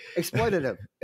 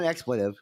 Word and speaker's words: exploitative: We have medical marijuana, but it's a restrictive and exploitation, exploitative. I think that's the right way to say it. – Yeah, exploitative. – Exploitative system. – Not exploitive.